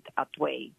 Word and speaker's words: Hatuey: a Taino man named Atuey, which is spelled H-A-T-U-E-Y.